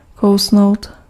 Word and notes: mordre: [mɔʁdʁ]

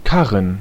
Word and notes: cart: /kɑːt/; /kɑɹt/

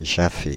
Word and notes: jaffer: /ʒa.fe/; France (Île-de-France)

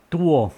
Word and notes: tuo: /ˈt̪uo/; /ˈt̪uoː/; /ˈt̪uo(ʔ)/; /ˈtuɔ/; /ˈtuɔʔ/

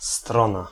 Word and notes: angle: American English; [ˈæŋ.gəl]

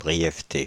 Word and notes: brièveté: /bʁi.jɛv.te/

